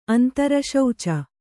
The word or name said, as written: ಅಂತರಶೌಚ